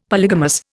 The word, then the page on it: polygamous: Indian